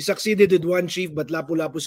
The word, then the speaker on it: Lapu-Lapu: Can you tell us about Lapu-Lapu? Yeah, I'm probably not pronouncing that correctly. I apologize. – No, don't worry. That is a Cebuano name. It's actually pronounced Lapu-Lapu.